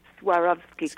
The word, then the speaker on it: Swarovski: Is it pronounced Swarovski? – Swarovski crystals.